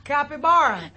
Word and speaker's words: capybara: What is a caipivara? – Caipavira? – Capybara? That's how you say it!